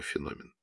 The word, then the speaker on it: феномен: После записи интервью она пришла к Фаине Георгиевне и сказала: — Все хорошо, но в одном месте нужно переписать слово «феномен». Я проверила, современное звучание должно быть с ударением в середине слова.